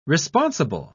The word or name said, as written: responsible